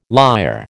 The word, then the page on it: liar: American English